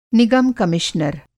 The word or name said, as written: निगम-कमिश्नर